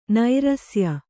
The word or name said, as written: ನೈರಸ್ಯ